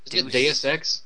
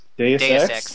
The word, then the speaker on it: Deus Ex: Do A or Do X or Deus Ex? – Deus Ex.